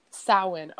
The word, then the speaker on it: Samhain: Samhain, okay.